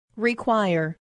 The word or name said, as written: require